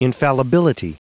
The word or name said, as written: infallibility